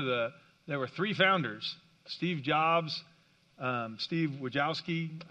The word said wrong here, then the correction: Wozniak: I probably butchered that. I'm sorry? Wozniak, thank you. Yeah, sorry, Steve Wozniak.